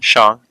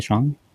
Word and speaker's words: Shang: Yeah, I'm pretty sure we mispronounced it, because I know in the movie he says it. – Shang. – Is it Shang? – Right. I think so.